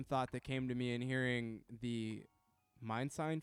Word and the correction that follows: Mind Design: feature, if I'm saying his name correctly. – Yeah, Mind Design. Yeah, Mind Design. Okay.